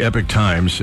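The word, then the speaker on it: Epoch Times: It's EPOC like epoch, but it's pronounced Epic Time.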